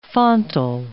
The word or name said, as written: fontal